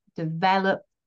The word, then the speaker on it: develop: So if we take a word like develop, which has three syllables, develop, we can hear that my stress is on the second syllable, the middle syllable, but that's really carried in the vowel e.